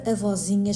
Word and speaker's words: avozinha: Se nós pronunciarmos a palavra pausadamente, verificamos que a sílaba tônica é a sílaba zi, a vozinha.